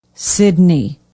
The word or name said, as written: Sidney